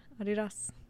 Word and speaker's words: Adidas: That's how we say it in our house is Adidas. – That's how it's technically pronounced. – Yeah. – Because his name was Adi.